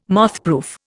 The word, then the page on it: mothproof: Indian